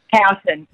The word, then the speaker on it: Houghton: Now, Gemma, is it Gemma Houghton or Gemma Horton? You pronounce it. – Houghton.